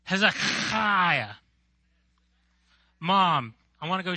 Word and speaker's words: Hezekiah: Before we can talk about Hezekiah, we need to learn how to pronounce it, right? Say Hezekiah. No, it's Hezekiah. Like a cat with a hairball back in the throat.